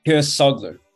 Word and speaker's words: Keisoglu: Keisoglu, but it's all good.